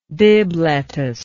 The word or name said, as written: bibb lettuce